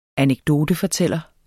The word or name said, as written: anekdotefortæller